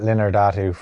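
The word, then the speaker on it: Linardatou: Linda Trow? Is that what it, that was a mistake? How I mistakenly pronounced it? – She's fighting Christina Linardatu for the WBO super lightweight title.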